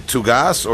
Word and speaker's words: Tougas: And right off the bat, Phil, just for clarity, I pronounce your last name Tougas, or is that incorrect? – It's technically correct.